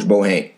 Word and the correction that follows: Boeheim: Coach from Syracuse. I'm sorry, Jim Boeheim, folks. Sorry for the mispronunciation of Coach Boeheim's name.